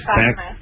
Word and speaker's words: Clackamas: Clacking Crack. – Clackamas. – What's that? – Clackamas. – Clackamas. Right.